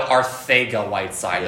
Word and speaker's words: Arcega-Whiteside: No, Arcega-Whiteside. Yeah. That's how they say it.